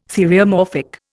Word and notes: theriomorphic: Indian

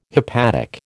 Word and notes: hepatic: American English

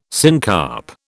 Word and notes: syncarp: British English

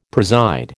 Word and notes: preside: American English

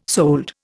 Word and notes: sold: Indian

